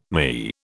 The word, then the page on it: ME: British English